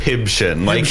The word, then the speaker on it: Hibshin: Hibshin. Hip and shin, but hip with a B.